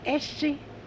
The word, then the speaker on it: Essie: Essie. – Okay. – E-S-S-I-E.